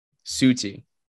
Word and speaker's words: Sauti: And then you have South Soul. Sauti Soul. Sauti. S-A-U-T-I. – Suti. – Sauti Soul. S-O-L.